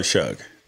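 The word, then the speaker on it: Shough: If you feel good about Anthony Brown being the quarterback of I literally have no ill will toward Tyler Shuck.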